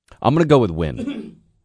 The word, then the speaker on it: Nguyen: Yeah, it's Nguyen. – It's just Nguyen? – Yeah. – Johnny Nguyen? – Yeah, Nguyen or Nguyen. – Well, which is it? – It's either one.